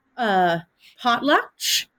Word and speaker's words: potlatch: You mentioned the term potlatch.